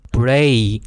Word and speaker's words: brave: 好，第三个常见的台式发音呢，就是 brave 勇敢的意思，双母音 brave，然后后面做一个是 v。的这种声音，这种震动，不要念成 breath，breath。